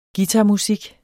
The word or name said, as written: guitarmusik